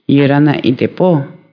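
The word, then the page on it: lachís tarachís: Caló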